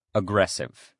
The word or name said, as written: aggressive